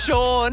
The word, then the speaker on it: Sean: This is Ross this morning with Nathan and Sean, Caitlin Bassett in. Caller Sean from Burns Beach called up. Caitlin letting us know she doesn't like the spelling of Sean, S-E-A-N. – I feel like Sean needs to have a W in it, though.